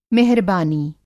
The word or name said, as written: मेहरबानी